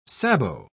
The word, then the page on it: sabot: British English